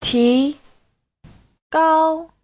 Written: ti2gao1

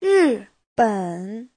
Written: ri4ben3

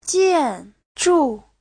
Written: jian4zhu4

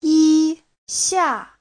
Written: yi1xia4

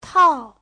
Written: tao4

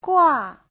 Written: gua4

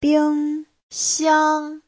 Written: bing1xiang1

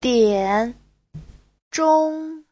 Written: dian3zhong1

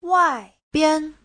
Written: wai4bian5

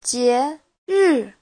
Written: jie2ri4

